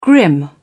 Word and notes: grim: British English

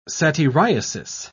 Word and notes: satyriasis: British English

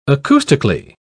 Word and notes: acoustically: British English

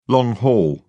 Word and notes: long haul: British English

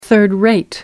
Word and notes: third-rate: American English

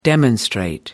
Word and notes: demonstrate: American English

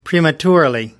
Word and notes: prematurely: British English

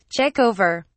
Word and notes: check over: British English

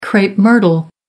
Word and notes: crape myrtle: American English

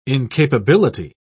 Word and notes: incapability: British English

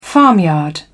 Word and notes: farmyard: British English

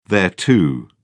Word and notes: thereto: British English

